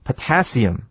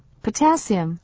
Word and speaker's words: potassium: 再来，我们看一个单字，就是 Robert 所说的这一段话里面的哈、啊，最后第二个字 potassium 这个字呢，它的念法蛮怪的，所以呢要注意重音在第二个音节，念作 potassium。 – potassium。